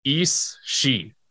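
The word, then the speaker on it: Aos Sí: And where it actually comes from is called, the spelling looks nothing like what it sounds like. Eos She. It's spelled like Eos C. It's actually Eos She.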